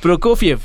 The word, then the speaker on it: Prokofiev: Prokofiev, lo dije bien.